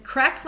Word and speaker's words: craic: Okay, yeah, so crack, when we say it, is spelled C-R-A-I-C. It's the shortened version of the Irish word crackina, which means fun.